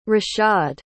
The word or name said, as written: rashad